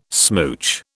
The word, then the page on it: smooch: British English